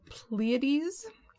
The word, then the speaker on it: Pleiades: Additionally, Merope was a member of the Pleiades. Hopefully I said that right. Yes. – No, you said that totally right.